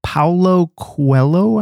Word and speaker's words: Paulo Coelho: Paulo Coelho, I think is the way you pronounce it.